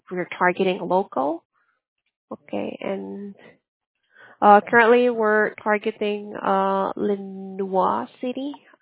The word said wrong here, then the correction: Lenoir City: I don't know how to pronounce this. It's Lenoir City. Lenoir City.